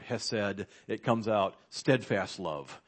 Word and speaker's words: hesed: There's this wonderful Hebrew word, I will pronounce it hesed, H-E-S-E-D, and that's what appears in both these instances of God's love towards David here.